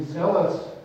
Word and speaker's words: zealous: I will be zealous. – Ooh, I like that word. Yeah. Zealous rhymes with jealous, so it makes it easy to remember.